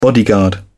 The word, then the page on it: bodyguard: British English